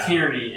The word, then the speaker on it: Tierney: It's like tyranny, except like two syllables. – So she's bad. – Right. – Tierney.